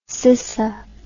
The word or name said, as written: cissa